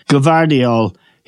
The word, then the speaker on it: Gvardiol: Gvardiol.